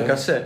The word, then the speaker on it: Κασέλ: Κασέλ δεν ξέρω πώ προφέρεται. – Σαμ Κασέλ. Ναι. – Κασέλ.